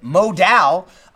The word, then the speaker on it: modal: It's very, very important you pronounce it modal.